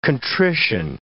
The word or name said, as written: contrition